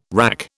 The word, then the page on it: wrack: British English